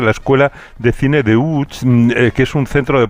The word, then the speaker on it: Łódź: Pues vamos entonces a Łódź, si te parece, para saber saber algo más de esta ciudad, aunque se pronuncia Łódź, se escribe Lodz, por si alguien lo busca.